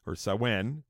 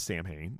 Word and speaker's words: Samhain: and that's what he did with Sawen, which is spelled Samhain. It's not pronounced Samhain, but we'll talk about that in just a sec.